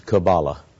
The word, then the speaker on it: Kabbalah: Hello, I'm John Van Auken, and in this video, we're going to study elements of the Kabbalah, a resource for soulful living, or you can pronounce it Kabbalah.